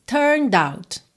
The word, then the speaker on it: turned: Não tem o som da letra E. Então, a gente diz turned out, turned out. Do N já vai direto pro D, tá?